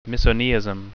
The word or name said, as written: misoneism